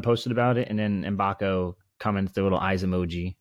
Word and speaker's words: Mgbako: We've clarified his last name is pronounced Mgbako. We kind of butchered that the last pod, but we won't do that again moving forward.